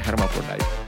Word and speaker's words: Hermaphrodite: Mungkin dulu bahasa Indonesia itu Hermaphrodite, tapi sebenarnya bacanya Hermaphrodite.